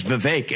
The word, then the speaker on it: Vivek: I say my name's Vivek. It rhymes with cake.